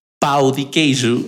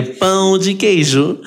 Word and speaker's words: pão de queijo: Cuando me cuentan que no estaba pronunciando correctamente la palabra, que estaba diciendo pau de queijo en lugar de pão de queijo.